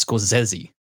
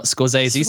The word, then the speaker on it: Scorsese: I was watching a podcast with the editor, and she says it's Scorsese, not Scorsese. Scorsese. This is the editor, Thelma Schoonmaker, who has edited basically all of Martin Scorsese's films. That's actually how you pronounce it. Is Scorsese.